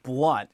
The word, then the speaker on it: Blount: And for your audience, by the way, and this is just hard for everybody, my last name is actually pronounced Blount, as crazy as it sounds, because when you see it spelled, it looks like it's spelled Blount, but it's Blount.